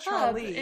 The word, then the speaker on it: Tralee: What? – The trolley pub. That's no, not trolley, like a little car. – No, the pub Tralee. In Mooresville. – Oh, yeah, but it's spelled T-R-A-L-E-E. That's why you're confused.